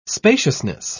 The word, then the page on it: spaciousness: British English